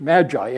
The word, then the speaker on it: magi: They are the word that's used in the Greek language is magi, at least that's how we pronounce it in English, magi, M-A-G-I.